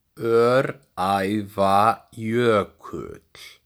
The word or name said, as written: Öræfajökull